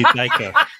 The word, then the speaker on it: Deicke: It was completely wrong, but it's fine. It's actually Deicke.